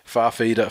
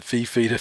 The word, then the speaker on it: Fifita: My favourite chant of the night, other than Slater's a wanker, was when everyone would break out into Farfida, Farfida, for no particular reason, even when he's just standing around within earshot. They would even say it wrong just because it sounds better than Fifita, Fifita, which just makes you sound like a bunch of cuzzy bros.